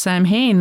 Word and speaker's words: Samhain: And that's when I first found out that, no, Samhain is not pronounced Samhain. And I'm like, what do you mean? That's how it's spelled. – Right. – And then it's like, no, it's Celtic. I'm like, oh, okay. – The MH is silent.